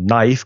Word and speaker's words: knife: Porque ya sabes que muchas palabras, como knife, que tiene una K que no se dice, como receipt, que tiene una P que no se dice, pues hay muchas palabras en inglés que son muy raras de pronunciar.